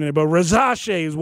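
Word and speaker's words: Risacher: Yeah, so he says Rizache. And you know, so the commissioner has people, you know, tell him exactly how to say it. So that's probably the correct pronunciation, Rizache.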